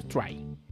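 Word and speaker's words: Tri: Y eso también pasó en el Monster Hunter Try, que sí, el nombre, pronunciación correcta es el de Monster Hunter 3, es Monster Hunter Try que de igual forma se lanzó en el 2009 para la Wii.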